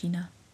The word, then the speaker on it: Jeena: Gina wordt wel als J-E-E-N-A geschreven. Dus J-E-E-N-A. E dus eigenlijk Jena Han. Maar het wordt uitgesproken als Gina.